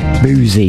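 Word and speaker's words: Busey: I don't think that's the right way to pronounce it, really. Boozy, yes. B-U-S-Y.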